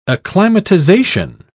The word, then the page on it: acclimatisation: British English